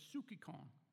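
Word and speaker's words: psychikon: If it's put into English, it's psukikon. And it's spelled, if you transliterate to English, it's spelled P-S-Y-C-H-I-K-O-N. P-S-Y-C-H.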